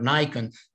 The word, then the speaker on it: Nikon: So this first steps of Westernization was connected with mainly with the church reform of Patriarch Nikon or Nikon, to use the English pronunciation.